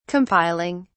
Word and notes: compiling: British English

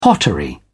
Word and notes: pottery: British English

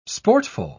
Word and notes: sportful: British English

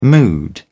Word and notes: mood: British English

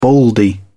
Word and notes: baldy: British English

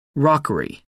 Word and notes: rockery: American English

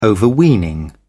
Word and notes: overweening: British English

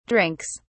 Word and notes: drinks: British English

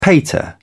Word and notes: pater: British English

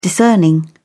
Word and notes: discerning: British English

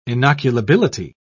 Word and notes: inoculability: British English

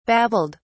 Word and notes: babbled: British English

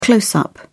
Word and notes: close up: British English

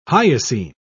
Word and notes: hyoscine: British English

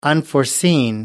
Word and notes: unforeseen: American English

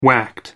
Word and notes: whacked: American English

